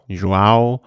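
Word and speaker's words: João: Jojo Almeida, wie spricht man? Joao Almeida vom The Koenig Quickstep, Chris Harper, Nelson Paulus vom Team Education Nippo.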